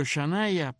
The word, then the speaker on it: Shania: Esto se escribe Shania, o sea, con SH, S-H-A-N-I-A. Yo no dudo que seguramente alguien escucha hoy ahora eso y le pondrá a la niña mañana, así. Se pronuncia Shania.